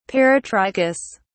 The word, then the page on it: peritrichous: British English